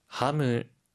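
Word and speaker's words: ハム: 하무, 하무, 하무, 햄인데요. 혹시 발음 팁 있을까요? – 하이, 소세지는 시가 아니라 세로 발음을 해야 하고요. 하무라고 할 때 끝의 소리를 무로 발음합니다.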